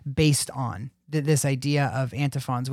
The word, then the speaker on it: antiphons: In church history, there's these things called, how do you pronounce it? Antiphons? – Yeah, antiphons.